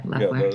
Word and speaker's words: Loeffler: You know the way you you all got what a Loeffler Leffler how do you say her last name? The, yeah, Leffler.